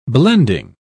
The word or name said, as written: blending